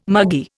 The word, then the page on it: muggy: Indian